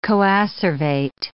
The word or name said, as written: coacervate